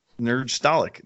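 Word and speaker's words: Nerdstalgic: It's nerd and, you know, nostalgic. – Right. Nerdgestalic? Nostalgic or something like that. – Nerdgestalic.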